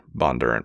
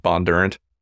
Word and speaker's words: Bondurant: By the way, everything I've heard, their names are pronounced Bondurant. To me, every time I look at it, it looks like some kind of French word, and I want to say bon durant, but apparently that's wrong.